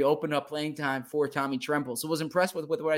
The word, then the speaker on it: Tommy Tremble: Tommy Tremble. – And, Tremble.